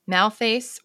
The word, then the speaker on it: Malthace: And Malthace was the third wife.